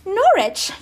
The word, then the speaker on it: Norwich: I was like an hour and a half north of London in between Cambridge and Norwich, because I was saying Norwich, because that's how it's spelled, Norwich. But I was corrected all the time. Norwich, you mean Norwich, you stupid American.